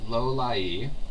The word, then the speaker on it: Lolai: We do have a resignation of the Director of Personnel Management, or OPM, Rear Admiral Krim Lolai. Yes, that's a correct pronunciation. I have the email in front of me. Lolai. Sounds like Lolliet.